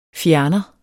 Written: fjerner

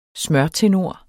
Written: smørtenor

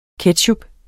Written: ketchup

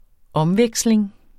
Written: omveksling

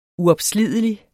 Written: uopslidelig

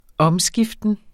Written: omskiften

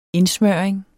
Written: indsmøring

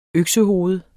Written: øksehoved

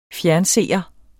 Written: fjernseer

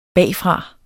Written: BAGFRA

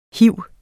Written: hiv

